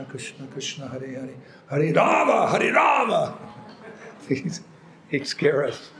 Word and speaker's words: Rama: It's Rama. Krishna's name is Rama. That's the proper pronunciation of the Mahamantra, the Yuga Dharma, the process for this age."